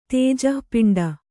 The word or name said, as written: ತೇಜಃಪಿಂಡ